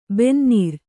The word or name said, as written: ಬೆನ್ನೇರು